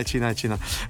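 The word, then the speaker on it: Cinà: Alcuni fanno la battuta, alcuni tolgono l'accento e lo chiamano Cina, però è un modo di farlo. – Cina. – Sì, sì, è Cina. Comunque, Federico ha perso il primo set 6-4, pur giocando anche abbastanza bene.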